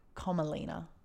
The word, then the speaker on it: Komalina: Did you say her name was Thumbelina? – Commelina. Like a Komodo dragon. – Like a comma? C-O-M-M-A? – K-O-M-A. L-I-N-A.